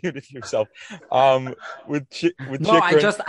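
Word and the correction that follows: Chytil: I just listened to like how they pronounce it. They pronounce it Chitrin.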